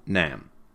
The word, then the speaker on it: NAM: I like the old non-aligned movement, or NAM. It rhymes with Vietnam.